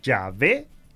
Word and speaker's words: Yahvé: Pues todos los hebraístas modernos están de acuerdo en que la manera original y primitiva de pronunciar el nombre de Dios debía haber sido Yahvé y no Jehová.